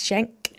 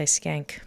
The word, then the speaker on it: Shank: I finished up there with a track from Adebisi Shank. I always say that wrong. I always want to say Skank, but it's not.